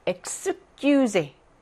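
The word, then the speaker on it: excuser: 그런데 뒤에 자음이 나온다. 그러면은요, 크스 발음이 나요. 그래서 엑스큐제, 엑스프리메 이런 식으로 크스 발음이 납니다.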